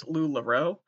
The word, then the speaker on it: LuLaRoe: I'd be so good in LuLaRue. Am I saying it wrong? Probably. I think it's LuLaRoe.